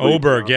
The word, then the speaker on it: Åberg: I thought, I don't know how I always mess up his pronunciation, Åberg or however. – Åberg, yeah, Ludvig Åberg.